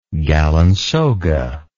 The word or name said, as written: galinsoga